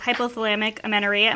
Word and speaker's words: hypothalamic amenorrhea: I know you struggled with hypothalamic amenorrhea.